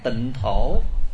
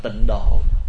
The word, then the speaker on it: tịnh thổ: Ví dụ như khi chúng ta viết chữ tịnh độ thì chúng ta viết ra chữ Hán là tịnh thổ,